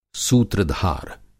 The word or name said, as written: सूत्रधार